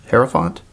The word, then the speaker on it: Hierophant: Hierophant? Hierophant? – Yes, right.